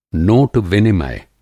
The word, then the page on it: नोट-विनिमय: Hindi